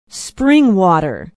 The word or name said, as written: springwater